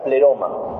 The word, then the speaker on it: pleroma: Una palabra que se pronuncia pleroma.